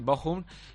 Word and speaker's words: Bochum: El disputado ayer en su concentración en Alemania ante el Bochum, que me dijo Mauri Díaz que hay que pronunciarlo así, Bochum. – Bochum. – Y no Bochum y Bochum.